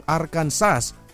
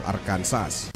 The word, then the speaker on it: Arkansas: Bawal mo ding bigkasin ang Arkansas with S ang Arkansas kahit na ang palatitikan nito ay A-R-K-A-N-S-A-S. Ang basa dito ay Arkansas.